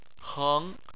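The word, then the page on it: heng: tone 2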